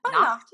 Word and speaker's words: Nacht: Your skincare line, and I'm probably butchering the pronunciation because it's German by Nacht. – Nacht. yeah, that's right.